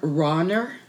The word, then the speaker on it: Rauner: But Governor Bruce, and I, please forgive me if I'm mispronouncing, R-A-U-N-E-R, Rauner? – Rauner.